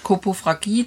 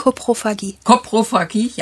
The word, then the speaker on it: Koprophagie: Jetzt sind denn diese Hunde, ich wage mich jetzt mal an das Fremdwort, Koprophagie, diese Kotfresserei, habe ich richtig gesagt? – Koprophagie, ja, ich wusste doch.